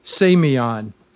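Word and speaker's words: semeion: In the Greek, the word is semion. I'll spell that for you. It's S-E-M-E-I-O-N.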